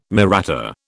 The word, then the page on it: Mahratta: British English